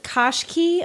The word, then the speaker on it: Qashqai: It's the Nissan Qashqai. Am I even saying it right? – That's something like that, yeah.